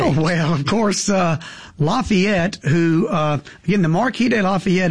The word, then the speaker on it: Lafayette: We pronounce it Lafayette in Mississippi.